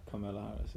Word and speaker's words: Kamala Harris: Yeah, Kamala Harris, yeah.